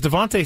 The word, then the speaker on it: DeVonta: I'm a little nervous about that because Devontae Smith has been pretty darn good lately. – I'm just glad that we're now pronouncing his name right. It was like a year of Devonta Cord because of Scraby.